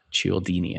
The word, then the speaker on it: Cialdini: The Psychology of Persuasion by Dr. Robert Cialdini. His last name is spelled C-I-A-L-D-I-N-I, Cialdini.